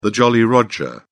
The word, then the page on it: Jolly Roger: British English